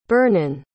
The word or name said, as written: Bernon